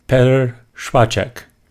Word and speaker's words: Špaček: We have Petr Špaček. How was that? – Very good.